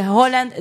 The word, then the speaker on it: Haaland: Tenía 20 y tantos años sin participar y nos deja de sorpresa pues un jugador de la talla, de la categoría de Haaland, o Holland, que es la pronunciación correcta de su apellido.